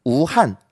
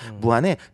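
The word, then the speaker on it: 武汉: 자 2위는 잘 모르실 텐데 이거는 우한. 한국어 발음 무한이라는 네네. 지역입니다. 무한에 그 热干面이라고 있어요.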